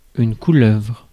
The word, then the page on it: couleuvre: /ku.lœvʁ/